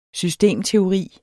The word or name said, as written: systemteori